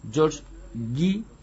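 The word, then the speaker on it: George Gey: Bueno, su muerte se relacionó casualmente con un doctor, con un médico, el doctor George Guy, se escribe G-E-Y,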